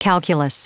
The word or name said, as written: calculus